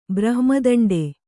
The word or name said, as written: ಬ್ರಹ್ಮದಂಡಿ